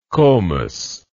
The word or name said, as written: komos